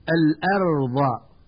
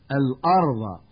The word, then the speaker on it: الأرض: والآن مع الدرس الثاني ومع القراءة الصوتية الثانية والتي سأحاول من خلالها تنبيه المتعلم إلى الأخطاء النطقية الشائعة في الكلمات التالية: الأرض، ويقول من يخطئ الأرض بتفخيم الهمزة،